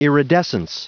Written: iridescence

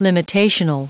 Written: limitational

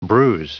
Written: bruise

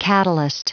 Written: catalyst

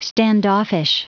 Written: standoffish

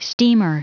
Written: steamer

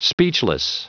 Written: speechless

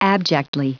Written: abjectly

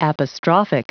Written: apostrophic

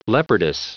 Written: leopardess

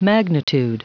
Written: magnitude

